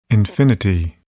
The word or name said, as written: infinity